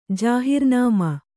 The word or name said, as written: ಜಾಹೀರನಾಮೆ